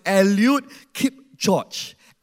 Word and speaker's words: Eliud Kipchoge: This guy, the correct pronunciation is Eliud Kipchoge.